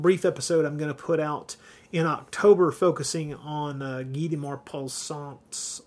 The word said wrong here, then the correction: Guy de Maupassant: I just mispronounced his French name, Guy de Maupassant.